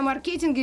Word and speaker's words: маркетинг: Э, физики и лирики спорят вообще очередной раз, но говорим сегодня о маркетинге или маркетинге. Ну, я говорю маркетинг, потому что по-английски такое ударение.